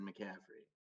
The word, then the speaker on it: McCaffrey: Yeah, look, here's what I'll tell you about Christian McCaffrey. His name rhymes with caffeine-free Coke. And he's exactly that. He's caffeine-free Diet Coke.